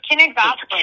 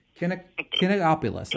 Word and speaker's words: Kinigopoulos: Kinigopoulos. – Should it be the other way around? – Kinigopoulos.